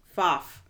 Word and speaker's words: Pfaff: Kristen Pfaff. it's like okay. a silent P in there.